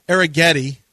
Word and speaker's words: Arrighetti: Well, John Spencer Arrighetti, which rhymes with spaghetti, is pitching for the Astros tonight.